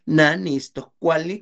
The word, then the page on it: na ni'istok kuali: Náhuatl